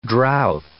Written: drouth

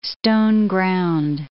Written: stone-ground

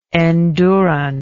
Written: endurant

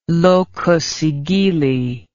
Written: locus sigilli

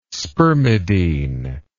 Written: spermidine

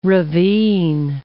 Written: ravine

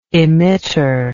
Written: emitter

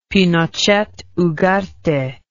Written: Pinochet Ugarte

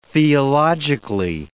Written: theologically

